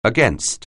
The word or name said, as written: against